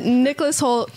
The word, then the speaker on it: Nicholas Hoult: Nicholas Holt, no controversy with that pronunciation.